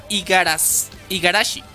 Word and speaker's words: Igarashi: Igarasashi. Pronunciaste mal. – Sayaka no mames, no me jodas. ¿Qué está pasando aquí? Ah, Sayaka Igarashi.